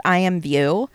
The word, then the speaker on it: IMVU: So it's actually it's pronounced IMVU. – Oh, okay. – Because it's an IMing website. It's like AIM, kind of.